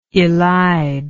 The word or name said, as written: elidible